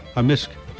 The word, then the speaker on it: amisk: Amisk. – Amisk. – Amisk. – So the K can be silent, but in general it's...